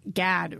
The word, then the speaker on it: Gad: Another really important part of that, the story that we haven't touched upon yet, is that Gad rhymes with Thad.